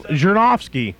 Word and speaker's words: Zhirinovsky: Yeah, we're going to call them up and see what they think about this Verzhenevsky guy. What's his name again? – I knew how to say it till you opened your mouth. Zhirinovsky. Vladimir Zhirinovsky.